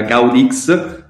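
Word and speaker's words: Guadix: Gioca la Coppa del Re, esce ai sedicesimi di finale contro un modesto, eh, qua sbaglierò la pronuncia, Gaudì, che è Gaudix, ai rigori.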